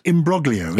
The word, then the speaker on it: imbroglio: And is that how you pronounce it? It's spelled imbroglio, isn't it? – It is. – But is that not correct? – It's a complicated situation. Imbroglio is Italian. Imbroglio. – Imbroglio. – Yes. – I-M-B-R-O-G-L-I-O.